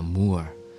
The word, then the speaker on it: Moor: Người Moran, tiếng Anh đọc là Moor,